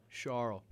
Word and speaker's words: Charles: It's spelled Charles, but it's Charles.